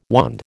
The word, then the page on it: wand: American English